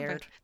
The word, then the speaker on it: Laird: So one is The Croning by Lard Baron, if I'm saying his name right. It's my first time reading. – Laird. – Thank you.